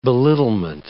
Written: belittlement

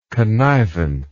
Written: connivent

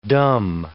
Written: dumb

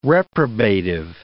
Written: reprobative

